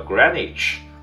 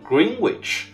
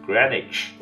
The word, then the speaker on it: Greenwich: 因为源于它的这个英语啊，英语其实它是呃正确读法叫 Greenwich，但很多人呢，因为有一个 W 在那儿，很多人会读成 Greenwich，啊，其实呢在这里呢，我要教大家一点啊，在这个词里面呢有一个特殊现象，也就是呢它的 W 是不发音的，所以标准的英语发音呢是 Greenwich，啊，再教一下 Greenwich，所以呢一中文的发音呢也应该是翻成是格林尼治大学。